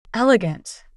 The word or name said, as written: elegant